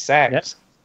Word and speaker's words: CeX: CEX, not as in a sex shop. Yeah. But apparently that's how you pronounce it. It's sex. apparently.